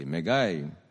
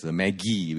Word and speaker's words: Magi: Or is it Magi, as in magician, M-A-G-I-C-I-N? But please don't pronounce it as Maggi, which is my favorite brand of instant noodles. Actually, the right way of saying this is Magi. You pronounce the G as a J.